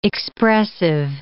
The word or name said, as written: expressive